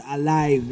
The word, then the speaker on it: Alyve: Then it's called Alive, as she said it. But then it's pronounced L-Y... – You mean... – I mean spelled. – A-L-Y-V-E.